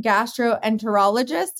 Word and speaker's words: gastroenterologist: You're a practicing gastroenterologist. Did I say that correctly? You did. Many people do not say it correctly.